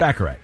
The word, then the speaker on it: Baccarat: Baccarat? – Baccarat, yeah.